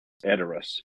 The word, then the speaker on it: Edoras: Am I saying that right? Edoras? – Edoras.